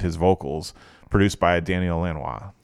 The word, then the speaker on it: Lanois: which is Daniel Lanois. And some people pronounce his last name Linois or whatever, looks like whatever.